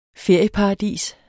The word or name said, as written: ferieparadis